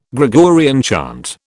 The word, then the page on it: Gregorian chant: British English